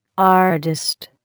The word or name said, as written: artist